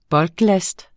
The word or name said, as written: bulklast